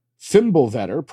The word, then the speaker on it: Fimbulvetr: Fimbulvetr, pronounced Fimbulvetr, in Norse mythology is three successive winters where snow comes from all directions, causing the end of the world.